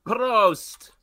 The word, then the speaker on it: Prost: Prost.